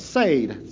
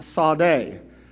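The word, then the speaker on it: Sade (צ): And if you'll look at it, you will be like me, and you will say, oh, it's Sayd, Sayd. But actually, it is pronounced Sade, Sade. That's how you say it. And actually, there's like a little t. With the T and S. It's Sade.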